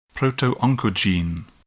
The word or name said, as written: protooncogene